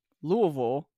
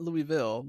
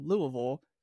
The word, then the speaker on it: Louisville: By the way, Louisville, not Louisville, Louisville is apparently the correct pronunciation.